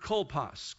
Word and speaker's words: kolpos: That word bosom is a Greek word that is actually pronounced something like kolpos, kolpos.